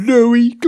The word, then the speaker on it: Lowe: Is that how you say it? Low or Lowy? – I'm sure low. It's low.